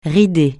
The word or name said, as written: rider